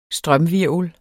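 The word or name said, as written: STRØMHVIRVLER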